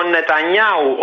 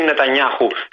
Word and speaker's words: Νετανιάχου: Ε, με την Αίγυπτο και τη Σαουδική Αραβία και τώρα που θα βγει και ο Νετανιάου, όπω προφέρεται, και όχι Νετανιάχου, στο Ισραήλ, θα είμαστε ξανά καλά.